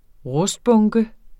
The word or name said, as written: rustbunke